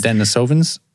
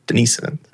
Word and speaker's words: Denisovans: Denisovans. That's how they say it. Yeah, because that's Denisovans. – Denisovans, because it's Russian. Denisovans.